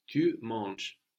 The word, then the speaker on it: manges: Tu manges. Are you eating? – Manges-tu? – Manges-tu? So, the form we have for tu, it sounds like manger without the E-R.